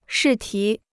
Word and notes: 试题: shì tí

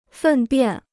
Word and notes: 粪便: fèn biàn

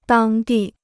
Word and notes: 当地: dāng dì

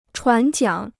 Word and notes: 船桨: chuán jiǎng

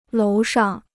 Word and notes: 楼上: lóu shàng

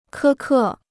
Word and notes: 苛刻: kē kè

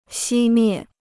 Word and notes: 熄灭: xī miè

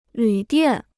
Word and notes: 旅店: lǚ diàn

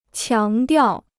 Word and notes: 强调: qiáng diào

